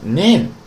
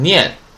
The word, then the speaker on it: 年: 哎，那么在这种情况下呢，读成 name，啊 ne 和 n，那 ni n ne no 的 ne 和 n，啊 ne n 读成 name，啊，那么不要读成念，不是念啊，是 name，那么。